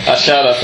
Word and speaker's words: Ayala: Ashala, for the benefit of Anglophones listening, is the proper pronunciation of the player you'll all know as Roberto Ayala, the Valencia centre-back and national team centre-back for a long time. Argentines pronounce Y as funny, so that's why he's called that.